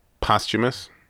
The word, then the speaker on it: posthumous: how do you pronounce this word, man? The after death album. – Posthumous. – I'm so bad at saying that. – Like, it looks like posthumous. – I always stumble on that word.